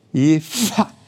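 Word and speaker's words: Jever: Vor allen Dingen musste ich mich erstmal belehren lassen, dass ich dieses wunderbare Bier immer falsch ausgesprochen habe. Ja, und es heißt auch nicht Jade Weser, sondern Jade. Jefa. Das machen die sogar in der Werbung falsch.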